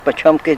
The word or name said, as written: bachomki